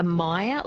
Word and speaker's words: Meyer: She says, does the iron issue apply to myoea as well? – So maybe a Meyer lemon, so Yeah.